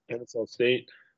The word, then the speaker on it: Kennesaw State: Next game, we got Xavier taking on, what is it, Kennesaw State? Did I say that right? – Yep, Kennesaw State.